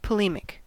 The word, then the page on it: polemic: American English